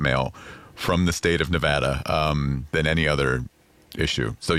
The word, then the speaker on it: Nevada: was the correct way to pronounce the state that Las Vegas is in, because a lot of politicians would say Nevada. – Wrong. – And that is wrong. – Wrong. It is Nevada. And I had to train myself to say Nevapple so I could pronounce the A right.